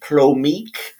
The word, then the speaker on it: plomeek: It's pronounced plomeek.